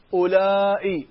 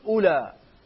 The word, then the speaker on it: أولاء: يعني أولاء فيها لغتان، بعض العرب يقولون أولاء بالهمز، يحطون الهمزة، وبعض العرب يقولون أولاء، يقولون أولاء ما ينطقون الهمزة، وهي لغة التميمي.